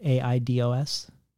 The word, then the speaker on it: eidos: It's about the ancient Greek word eidos. Is that how you pronounce it? Aidos.